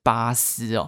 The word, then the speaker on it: Bass: 第一个是长得像肯德基爷的洋将是 Randy Bass 啊，日文的发音应该是巴斯哦。他说他是看日剧学到的。” 第二个呢，是二零零三年打败阪神虎的是福冈软体银行鹰的前身大荣鹰。好，他也感谢派翠克制作优质的节目。好，受教了，原来是念巴斯，我一直以为就是念贝斯啊。